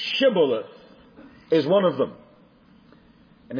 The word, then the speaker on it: Shibboleth: Then said they unto him, Say now, Shibboleth. And he said, Sibboleth. For he could not free him to pronounce it right.